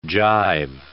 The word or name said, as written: jive